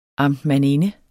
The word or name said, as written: amtmandinde